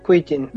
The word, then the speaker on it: Kuijken: O sea, yo vi como que lo que leí de la noticia, que es un artículo, un preprint de Cohen Kijen, no sé cómo se pronuncia, es del Observatorio de Leiden en los Países Bajos, Kuijken. ¿Cómo lo pronuncio? – Kuijken. Okay.